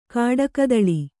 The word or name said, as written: ಕಾಡುಕಿತ್ತಲೆ